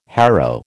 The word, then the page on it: harrow: American English